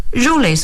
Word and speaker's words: Jules Verne: The other was entitled, 就是说 The other book was entitled Around the Moon, 围绕地球。这两本书呢，在中国啊，有些读者可能读到了，第二本可能没有翻译。但是呢，这些书呢，都是在描写什么呢？In these books, Jules Verne, that's the French way of it, Jules.